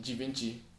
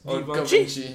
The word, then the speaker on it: Givenchy: I mean, there's also people who pronounce Givenchy, Givenchy. – It's Givenchy.